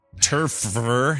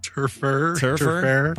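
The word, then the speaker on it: Terfehr: Spokesman Doug Turfer. How do you say that name? – Turfer. – Probably just Turfer. – Turfer. – That's a rough name. – That is, yeah. – Doug Turfer. It's T-E-R-F-E-H-R.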